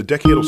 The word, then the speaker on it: decadal: known as the Decadal, is that how you say it? – Decadal. – Referring to 10 years, decadal. They release one only every 10 years.